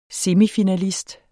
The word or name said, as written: semifinalist